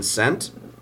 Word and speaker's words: scent: Is the S or C silent in scent? – Huh. – The C is silent.